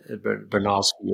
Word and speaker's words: Bronowski: And, you know, even outside of science, if you look at people like one of my heroes, Jakob Bronowski, another name that I'm meant to pronounce, Bronowski or something. But, I think you've got it.